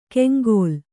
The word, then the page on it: ಕೆಂಗೋಲ್: keŋgōl; Kannada